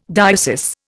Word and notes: diocese: Indian

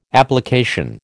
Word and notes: application: American English

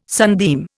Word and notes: sunbeam: Indian

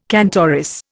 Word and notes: cantoris: Indian

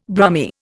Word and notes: Brummie: Indian

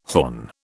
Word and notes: horn: British English

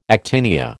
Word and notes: actinia: American English

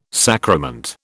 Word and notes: sacrament: British English

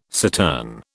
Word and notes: cittern: British English

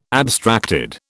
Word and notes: abstracted: British English

